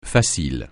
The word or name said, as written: facile